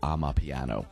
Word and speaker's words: Amapiano: That is a style of music called Amapiano, and it's spelled A-M-A-P-I-A-N-O, just like it sounds.